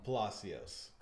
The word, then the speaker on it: Palacios: I'm joined by Robert Palacios, which I say like an Italian, but I know it's not a, it's a more Spanish close.